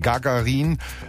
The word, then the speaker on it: Gagarin: Die ersten Signale, die ich also hier ins ewige Eis des Sektors senden möchte, kommen von Leuten wie zum Beispiel dem Briten Gagarin, so wird er ausgesprochen,